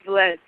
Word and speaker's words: Velez: Is it Velez? I'm terrible at pronouncing her surname. But yes, Ivelisse. – Yeah, Ivelisse Velez, yeah.